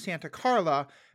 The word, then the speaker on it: Santa Carla: I also appreciate you pronouncing it properly as Santa Carla, because the mayor of Santa Cruz didn't want his town depicted.